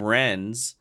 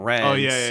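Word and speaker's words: Rennes: But they do a really good job of, like, justifying that, because as he's saying that, Quentin keeps calling him Renz. And the guy's like, "My name's not Renz. It's Ren. It's French. You don't pronounce the S at the end."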